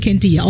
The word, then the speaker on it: Candia: You know, I mean, how hard is it just to say Candida? The name is... It pronounces it for you, right? Candia. All the syllables are right there.